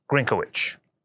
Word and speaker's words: Grynkewich: In fact, I had to learn the pronunciation of his actual last name because I've never heard it pronounced out loud. It's General Alexus Grynkewich.